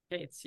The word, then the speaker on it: Kates: It's Cates, right? – Kates, yes. My grandfather Yes. – used to be na it used to be spelled Katze,